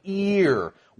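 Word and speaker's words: ear (ἀήρ): not E-A-R, but Alpha, Eta, Rho, pronounced ear.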